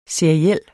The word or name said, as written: seriel